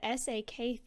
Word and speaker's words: SAK: You ended up leaving home at 19 to join the SAK Theater. Can you tell me about that experience? Sure. – So it's called SAK, SAK Theater.